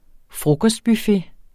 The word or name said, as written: frokostbuffet